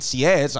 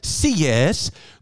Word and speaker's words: Sieyès: Then the second coup was Napoleon didn't tell his other two boys about this, who were Roger Ducos and Sieyès. I don't know how they'll spell that, how to pronounce that, but it's spelled S-I-E-Y-E-S. Sieyès.